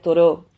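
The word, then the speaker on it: Thoreau: En vez de, decía Toró, que, se escribe T-H-O, Toreau, Toró decía,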